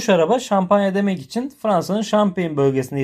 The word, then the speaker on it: Champagne: Champagne diye okunuyor.